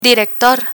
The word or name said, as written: director